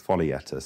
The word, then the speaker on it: Foglietta: Foglietta. So Foglietta.